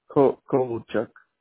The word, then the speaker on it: Kovalchuk: K-O-V-A-L-C-H-U-K. – C-H-U-K. Okay. And that's Kovacek. Is that how you say it? – Kovacek. – Kovacek. All right. And that's K-O-V-A-L-C-H-U-K.